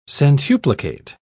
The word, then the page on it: centuplicate: British English